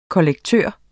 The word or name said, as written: kollektør